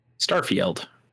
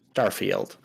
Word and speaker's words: Starfield: Also, if you're wondering, it is not pronounced like Garfield. – Starfield. Everybody knows that. – It's pronounced Starfield.